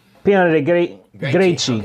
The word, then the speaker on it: Piana dei Greci: Mussolini visited Sicily in 1924, and he passed through this one little town of Piana dei Greci. Would that be how you pronounce that? G-R-E-C-I? – Piana dei Greci. Okay. It'd be like C-H.